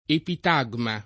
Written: epitagma

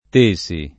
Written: Tesi

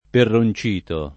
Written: Perroncito

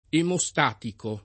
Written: emostatico

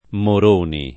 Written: Moroni

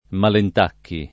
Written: Malentacchi

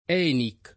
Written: ENIC

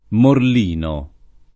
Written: Morlino

